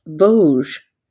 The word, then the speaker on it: Vosges: Think of the word loge like a theater seat, but with the letter V in front, Vosges.